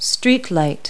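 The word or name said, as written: streetlight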